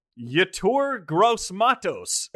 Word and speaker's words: Yetur Gross-Matos: And they do their best when they take Yetur Gross-Matos out of Penn State.